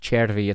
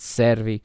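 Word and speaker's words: Cervi: I know Portuguese announcers like to lose those size players' names, but the name is pronounced Chervi. It's not Servi, but that's, you know, neither here nor there.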